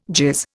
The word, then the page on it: jizz: Indian